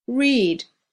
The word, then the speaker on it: read: Read, read, pronounced like the color red.